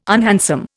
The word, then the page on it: unhandsome: Indian